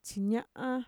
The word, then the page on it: txiña'a: Mixtec